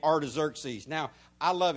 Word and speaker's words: Artaxerxes: But that's Artaxerxes is how you pronounce that.